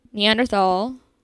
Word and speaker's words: Neanderthal: Neanderthal, it's how you think you would pronounce it because it's a TH. It's Neanderthal. No TH. Sound like the.